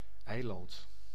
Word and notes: eiland: /ˈɛi̯.lɑnt/

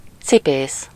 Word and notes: cordonnier: /kɔʁ.dɔ.nje/

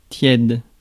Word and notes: tepid: /ˈtɛp.ɪd/

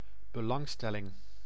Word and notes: concern: American English; [kənˈsɜːn]

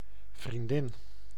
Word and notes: vriendin: /vrinˈdɪn/

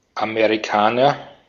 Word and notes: American: /əˈmɛɹ.ɪ.kən/; /əˈmɛɹəkən/

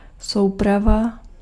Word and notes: ensemble: France

